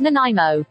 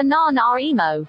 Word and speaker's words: Nanaimo: It's pronounced Nanaimo. What the f- is a non emo.